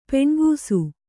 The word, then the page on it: ಪೆಣ್ಗೂಸು: Kannada